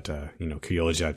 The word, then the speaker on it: Criolla: But, Criolla Chica is spelled C-R-I-O-L-L-A, and it still has that J sound.